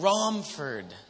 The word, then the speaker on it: Romford: When I got to Rumford, I said that wrong? Rom. – Not rum or rum. – Rom. Romford. See, I just got done saying I figured out how to pronounce the names.